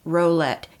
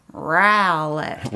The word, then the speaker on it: Rowlett: When you see Rowlett, it looks like Rowlett. It is not pronounced Rowlett. You have to say it with a growl. A Rowlett. – Rowlett. – Just look at the owl in the middle of it and make sure you say owl.